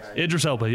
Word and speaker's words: Idris Elba: Is that how you say his name? – Idris Elba, yeah, you got it.